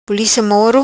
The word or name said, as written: Pulica Moro